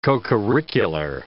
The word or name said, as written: cocurricular